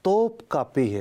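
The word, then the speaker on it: Topkapı: لفظ توپ کاپی ہے، میں بھی بعض اوقات جو ہے ٹوپ کاپی پڑ گیا، لیکن صحیح تلفظ جو ہے وہ توپ کاپی ہے.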